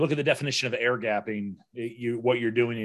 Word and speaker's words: air gapping: Um, is uh, Hastings is asking Is ear gaping? Am I pronouncing it right? Ear gaping? – Uh, um, air gapping.